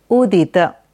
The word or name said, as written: Udit